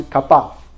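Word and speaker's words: kabad (כבד): 叫 kabad 啊，希伯来文发音是 kabad，是有分量，有尊荣，得荣耀，得尊荣，是这样的一个意思。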